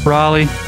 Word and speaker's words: Raleigh: How do you pronounce it down there, asshole? – Raleigh.